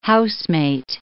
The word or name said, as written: housemate